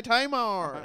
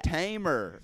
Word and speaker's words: Tamar: You didn't say Tamar. – Oh, Brax. – Now I know. Now I know who it is. – Oh, Tamar now? – You weren't saying it right. – You weren't saying it right. He didn't say it right. – Yeah, I thought you said tamer. – Like a lion.